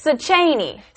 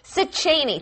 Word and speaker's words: Szechenyi: As far as I know, it's Szechenyi. – Szechenyi.